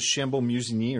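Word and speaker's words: Chambolle-Musigny: This is the village is Chambon Musigny, or the wine Chambon Musigny.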